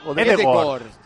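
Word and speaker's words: Ødegaard: Ordegar. – No digas nada. – Por eso, Ordega. – Arriba el balón de Pepe. Fuera para el Biol. – ¿Cómo se dice? Odegaard, ¿no? – Edegor.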